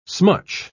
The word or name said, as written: smutch